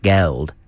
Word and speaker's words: Gauld: G-A-U-L-D. How would you pronounce that? Gold. I like the way you said it before. Geld.